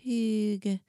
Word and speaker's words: Hygge: It's spelled weird. It's Hygge, but it's pronounced Hygge.